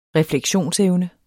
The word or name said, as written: refleksionsevne